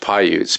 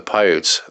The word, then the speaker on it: Paiutes: I think it's pronounced Paiutes, P-A-I-U-T-E-S.